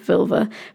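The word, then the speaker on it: völva: You can say that word however you want. I'm going with this one because Ted the Viking in Glastonbury, shout out to him, told me how to say it. And the other way I said it always made it sound sexual, so I'm going to go with Vilva because it makes my life easier.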